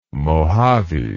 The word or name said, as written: mojave